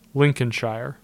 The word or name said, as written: Lincolnshire